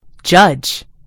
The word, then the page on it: judge: American English